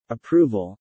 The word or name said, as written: approval